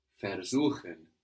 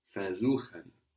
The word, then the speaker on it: versuchen: Versuchen. It's spelled with a V, but that V is an F. – Uh-huh. – Versuchen.